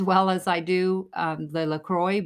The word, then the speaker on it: LaCroix: I'm saying that wrong too. That is LaCroix.